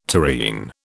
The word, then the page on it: tureen: British English